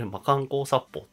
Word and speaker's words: 魔貫光殺砲: あ、これ魔漢光殺法って読むんだって、うんなんか中学かなんか小学校かの時に思って、なんかすっごいインパクトあったんですよね。